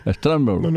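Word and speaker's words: Strasburg: Stremberg. ¿Cómo se pronuncia? Stremberg. – No, está muy bien.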